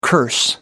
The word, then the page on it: curse: American English